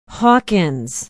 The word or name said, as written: hawkins